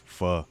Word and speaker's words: pho: Those who don't know, you think it's called pho, but it's actually called pho.